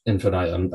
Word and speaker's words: Infinitum: Ad Infinitia, I don't know how to actually pronounce that one. Ad Infinitum is another it's survival infinitum, horror. Infinitum, isn't it? – Infinitum. Okay. – Infinitum, I think. – Okay, that makes sense.